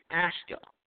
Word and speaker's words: Asuka: and I think you pronounce his name Asuka.